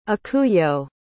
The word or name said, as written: ikuyo